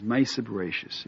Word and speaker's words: Maaseh Bereishis: Well, the way they pronounce it in Yeshiva is Maaseh Bereishis.